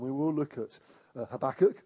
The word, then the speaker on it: Habakkuk: And uh, he talked about the book of Habakkuk except he pronounced it Habakkuk because he was American, and uh, half of his message was trying to get the pronunciation right. But I know it's Habakkuk, not Habakkuk, apparently.